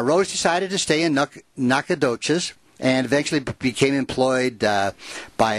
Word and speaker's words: Nacogdoches: Did I say that right? – Yeah, Nacogdoches. – Nacogdoches.